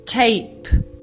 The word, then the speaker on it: tape: Se escreve T-A-P. – Tap. – Se você botar um E final, a pronúncia muda para tape. – Tape, fita, fita cassete, tape.